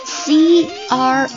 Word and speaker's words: croissant: 因为法语当中这个 C R O I 放在一起叫就读作 qua， cro， cro。 Croissant， OI 放在一起通常是发作 I 音的，但是我会偏向最后一个读音 Croissant， Croissant。以后到面包店 Bakery 去买东西的时候，应该就会知道这个牛角包或羊角包怎么说了吧。 Croissant， Croissant， Croissant。